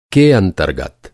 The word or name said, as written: अंतरगत